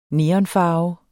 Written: neonfarve

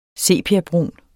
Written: sepiabrun

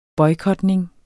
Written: boykotning

